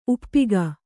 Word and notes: ಉಪ್ಪಿಕ್ಕು: Kannada

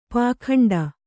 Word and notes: ಪೌಗಂಡ: paugaṇḍa

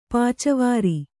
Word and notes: ಪಾಚವಾರಿ: pāca vāri

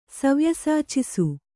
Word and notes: ಸವ್ಯಸಾಚಿಸು: Kannada

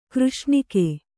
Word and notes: ಕೃಷ್ಣಿಕೆ: křṣṇike

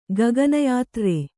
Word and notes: ಗಗನಯಾತ್ರ್ರೆ: Kannada

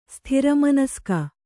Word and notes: ಸ್ಥಿರಮನಸ್ಕ: Kannada

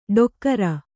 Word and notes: ಡೊಕ್ಕರ: ḍokkara

